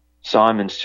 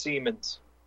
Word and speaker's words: Siemens: It's pronounced Siemens. – Yes, I mispronounced that.